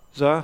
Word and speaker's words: czar: C-Z-A-R. Tsar, thank you. The Russian Tsar.